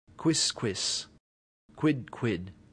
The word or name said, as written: quisquis